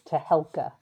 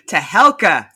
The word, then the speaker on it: Tehelka: And off the top, please correct my pronunciation of India's weekly nice to be here, and it's Tehelka. – Tehelka, thank you very much.